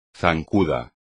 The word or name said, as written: zancuda